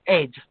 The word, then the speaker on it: head: And the reason it was called Eddie, as you probably know, is that because in Cockney you don't pronounce the H of head, and so it became Ed. the band's Ed, and it became Eddie, and there we go.